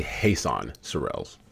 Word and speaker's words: Jesan Sorrells: Have I pronounced your name right? Jesan? – It's actually Jesan Sorrells.